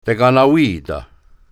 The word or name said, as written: Tekanawita